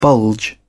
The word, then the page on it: bulge: British English